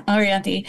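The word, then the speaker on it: Orianthi: But then I said, Orianthe. Am I saying her name right? Is that how you say it? – Yeah, Orianthe.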